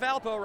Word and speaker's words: Valpo: Some people just call it Valpo, rhymes with Alpo.